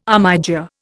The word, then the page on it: armiger: Indian